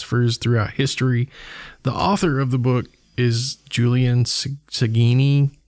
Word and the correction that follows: Baggini: Or I may be saying that wrong. – Baggini. Yep. Julian okay. Baggini.